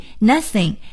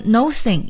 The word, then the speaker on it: nothing: 最后再来看一看另外一个房间，可能我们说这房间里啊现在是空的，什么都没有，什么都没有，我们可以用 nothing N-O-T-H-I-N-G nothing thing 是东西的意思， no no 是这个没有的意思，我们不能念成 nothing，一定要念成 nothing nothing。